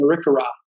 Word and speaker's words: Arikara: He is a member of the, correct me if I don't pronounce this right, Mandan Hidatsa and Arikara Nation. You want to say those for me? – Yeah, pretty close. Mandan Hidatsa and Arikara. – Oh, Arikara.